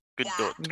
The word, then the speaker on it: Gadot: Gadot. – Gal. – Gadot. Check. Gadot. You already know how to pronounce that. The second syllable is dot.